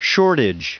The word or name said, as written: shortage